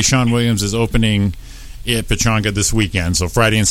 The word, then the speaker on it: Pechanga: Is that how you say it? – Pechanga.